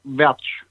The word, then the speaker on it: Wertsch: It's Greg Welch. – There we go. Because if you read it in English, it says worst.